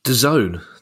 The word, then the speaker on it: DAZN: DAZN. That's how they're pronouncing. Is it DAZN? – That's right. Yeah, I'm with the kids.